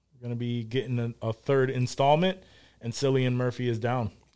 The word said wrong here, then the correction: Cillian: Killian? – Yeah, Killian. I don't know why I always say Cillian. – That's how I pronounced it for a while, until I heard it pronounced properly.